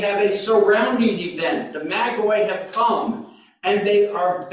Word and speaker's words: Magoi: I'm trying to help you learn not to use Magi. Use Magoi. That's the Greek. So I can say, do what? You're not saying that right. It's Magi.